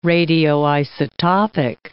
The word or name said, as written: radioisotopic